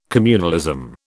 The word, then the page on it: communalism: British English